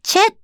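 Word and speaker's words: 鵲: そのランでチェっていう字は多分台湾の言葉なんでしょうね。昔に鳥と書いてランチェと読みます。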